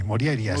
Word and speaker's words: Morjaria: Bueno, la J normalmente se pronuncia como I, Morjaria.